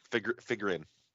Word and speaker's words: Figrin: Is that how you pronounce it? I probably should get that right. – Figrin. – Figrin.